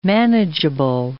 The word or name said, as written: manageable